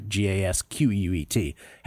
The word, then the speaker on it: Gasquet: So once this match ends, then, by the way, pronounced in English, it would be Richard Gasquet, spelled, right? Gasquet.